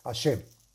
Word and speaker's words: Hashem: Y deberá meditar el santo nombre de Heishin Mem, que se pronuncia Hashem.